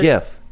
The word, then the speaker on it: GIF: Inventor of the GIF. I don't actually know who invented the GIF, but I do know that he pronounces it with the soft G.